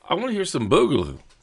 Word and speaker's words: boogaloo: Boogaloo is how it's pronounced.